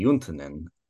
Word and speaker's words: Juntunen: Well, it's a Finnish name, and so actually correctly would be Juntunen. But when my great-grandparents came, it became Juntunen. So here we are.